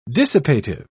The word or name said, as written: dissipative